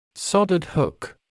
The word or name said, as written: soldered hook